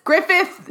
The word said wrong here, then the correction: Griffiths: It's a problem because it's like ownership. So there's an S on the end. – Griffiths.